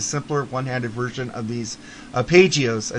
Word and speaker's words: arpeggios: How do you say that word? You've heard it say it. – Arpeggios. What is it again? – Arpeggios. I've heard this word before, and I'm not saying it right.